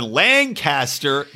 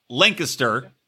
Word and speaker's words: Lancaster: So this takes case in Lancaster, not Lancaster, as we pronounce it here, Lancaster. And the post title is Lunar. L-O-O-N-E-R, which is why I typed it, because I was like, what the fuck are they talking about?